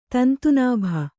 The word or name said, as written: ತಂತುನಾಭ